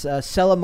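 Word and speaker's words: Selah: So it says Sela, is it Sela? I'd imagine Sela. It's pronounced Sela Marley.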